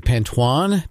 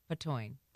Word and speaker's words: Patoine: Is it Pantoine? P-A-T-O-I-N-E? Is it Pantone? – P-A-T-O-I-N-E. Patoine.